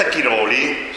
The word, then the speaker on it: Chiroli: Roberta Chiroli, sto azzeccando l'accento, sì? – Sì, ho messo mesi per non sgarrare qui.